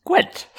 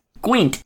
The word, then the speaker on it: Gwent: Oh, Gant? You play Gant? – It's pronounced Gwent. – Oh, you both can like I said, Gant. – Gwent.